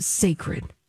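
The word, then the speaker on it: Cécred: I believe it is pronounced Sacred. – Okay. – It's spelt a little different than the word sacred, but that's what I'm going with. It's C-E with an apostrophe, C-R-E-D.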